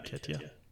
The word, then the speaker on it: Keita: How do you pronounce his name? – Naby Keita. – Keita? Okay, yeah. So Keita. Keita.